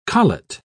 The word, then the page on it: collet: British English